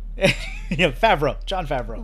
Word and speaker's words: Favreau: How do you pronounce his last name? – Favreau. – Favreau. – Yes. – Because I keep wanting to think, I keep thinking of Brett Favre.